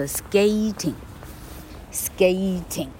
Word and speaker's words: skating: 因为老课说去 i n g 补一以后，它进入第三式，第三式它就要念作 a，于是念作 skating，skating，既然是 a，它会只有一个 t。呃，我们来研究 raining 这个大部分的人人没办法拼对的字哈，下雨 rain。